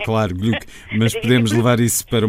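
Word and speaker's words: Glück: E o que eu acho muito interessante na poesia de Louise Gluck é o facto de ela cruzar, ou Louise Gluck, como eu também já tenho ouvido dizer.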